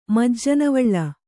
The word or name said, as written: ಮಜ್ಜನವಳ್ಳ